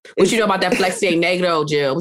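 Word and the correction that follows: Freixenet: Girl, first of all, that's pronounced fresh in that.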